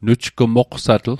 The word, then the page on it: nujgmoqsatl: Mi'gmaq